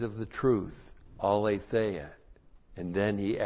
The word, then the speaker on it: aletheia: Aletheia, A-L, long E-T-H-E-I-A. Aletheia.